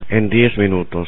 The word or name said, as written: en 10 minutos